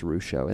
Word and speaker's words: Ruscio: I know I said it wrong the last time, and he corrected me, and it's Russo, and that's what I said.